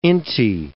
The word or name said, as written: inti